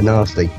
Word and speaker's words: Nesty: It's spelled N-E-S-T-Y. It might pronounce Nasty, but it's N-E-S-T-Y, Nasty.